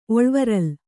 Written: ಒಳ್ವರಲ್

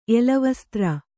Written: ಎಲೆವಸ್ತ್ರ

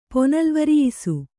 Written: ಪೊನಲ್ವರಿಯಿಸು

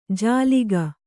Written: ಜಾಲಿಕ

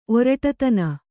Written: ಒರಟುತನ

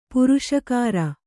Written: ಪುರಸ್ಕಾರ

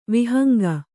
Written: ವಿಹಂಗ

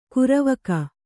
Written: ಕರಿವಕ್ಕಿ

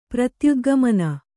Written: ಪ್ರತ್ಯುದ್ಗಮನ

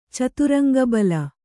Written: ಚಾತುರಂಗಬಲ

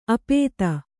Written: ಆಪಾತ

